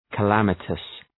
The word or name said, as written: calamitous